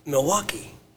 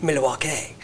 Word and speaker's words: Milwaukee: But they go in there and they say, Milwaukee. And they give that little spiel about Milwaukee. – Yeah. – Excuse me. And Alice Cooper goes, well, actually, it's pronounced Milwaukee.